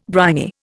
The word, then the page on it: briny: Indian